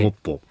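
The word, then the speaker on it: 木浦: で、パク氏ですね、はい、韓国南部のモッポというところの選出なんですけど、木の裏と書いてモッポ。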